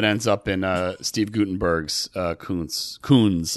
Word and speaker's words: Koons: So you're probably like, oh, you're going to see a Jeff Koons exhibit. You also gave Jeff Koons' name a real Dean Koontz pronunciation there.